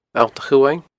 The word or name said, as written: Allt a' Chumhaing